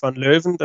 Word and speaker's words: van Leeuwen: Bo Greaves natürlich dabei, Mikuru Suzuki dabei, Fallon Sherrock, Robin Byrne, Ryan O'Sullivan, Lisa Ashton, Eileen de Graaf und Noah Lynn van Leeuwen das ist van Leeuwen, spricht man so aus.